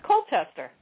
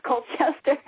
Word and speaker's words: Colchester: this looks to me, my Midwest eyes see this as Colchester. – Oh, it's Colchester. It is Colchester. – Oh, it is pronounced Colchester.